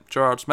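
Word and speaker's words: Gerads: Is that a soft G or a hard G? – I think it's Gerrards. – Yeah, I'd say soft. Right, Gerrards. Mitch Gerrards.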